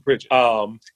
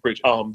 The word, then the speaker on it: Pridgen: Facebook, Joseph Pridgen. Instagram, Joseph Pridgen. LinkedIn, Joseph Pridgen.